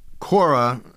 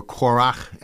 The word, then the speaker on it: Korach: Korah, who is what this is about, what I'm about to read in Numbers, or Korach, as it's pronounced in Hebrew,